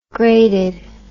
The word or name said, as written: graded